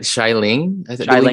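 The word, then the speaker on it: Xialing: Shailing. Shailing is how you say Ling, it.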